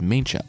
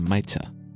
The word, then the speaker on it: Maicha: I pronounced it as Maincha, and it's really closer to Maicha. Also, it's Punku Maicha, with Punku being her name, and Maicha meaning little girl.